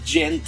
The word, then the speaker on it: Djent: Y es que también el nombre que se usa para designarlo es Djent, ¿no? Que se escribe D-J-E-N-T, y que en realidad no quiere decir nada, no es una palabra así que se encuentra en el diccionario ni nada así.